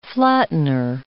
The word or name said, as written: flattener